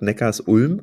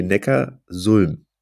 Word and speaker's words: Neckarsulm: Und deshalb Neckars, also man müsste es ja dann Neckarsulm aussprechen. Ich habe immer gedacht Neckarsulm, aber nee, Neckarsulm.